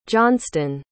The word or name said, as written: johnston